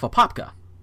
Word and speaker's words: Apopka: And this next one is out of Apopka. And that is how it's pronounced.